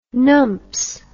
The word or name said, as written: numps